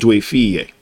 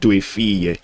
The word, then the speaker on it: Due Figlie: It's called Due Figlie. The G is silent, but you enunciate Due Figlie. Some people say Due Figli.